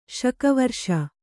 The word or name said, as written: ಶಕವರ್ಷ